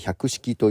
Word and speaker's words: 100s: で、そのバンドを組んで、ライブをするんですけども、 100S と書いて 100 式という、